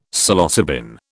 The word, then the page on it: Psilocybin: British English